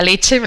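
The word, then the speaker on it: Lecce: leche es, L-E-C-C-E, e, se pronuncia leche,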